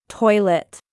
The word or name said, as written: Toilet